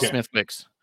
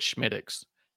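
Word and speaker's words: Smithwick's: It's spelled Smithwicks, and everyone thinks it's Smithwicks. And it's a Guinness product, but it's really good. But when you go to a bar and you're trying to impress the bartender, you call it Schmidix.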